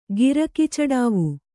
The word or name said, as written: ಗಿರಕಿಚಡಾವು